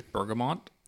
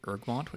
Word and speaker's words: bergamot: Now, I'm going to say it wrong. I'm pretty sure it's bergamot. – Yeah. – Years I spent saying ergamot, which apparently is not a thing.